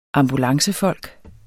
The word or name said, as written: ambulancefolk